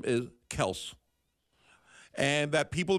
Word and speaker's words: Kelce: but the actual pronunciation is Kels. – Tell his sons that, because they pronounce it wrong, too.